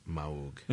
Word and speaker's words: Moog: It's pronounced Moog.